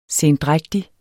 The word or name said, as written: sendrægtig